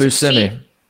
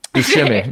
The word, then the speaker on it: Buscemi: Oh, this is I don't know how to say this last name, but I it's Buscemi. – Buscemi.